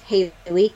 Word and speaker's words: Haley: Okay, so Twitter and Instagram, it's at Haley Carrots, H-A-L-E-Y Carrots.